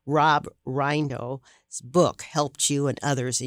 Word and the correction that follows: Rienow: It's actually Rob Reno. – Reno. – R-I-E-N-O-W.